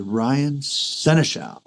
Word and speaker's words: Ryan Senechal: But not only that, from Vancouver Island, joining us today is Ryan Senechal.